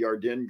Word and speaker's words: Yarden: And I think that's an interesting thing that you bring up about the Yarden girl. And Ari says in the chat, Seth, that that's the Israeli way to pronounce it, that you nailed the pronunciation just right.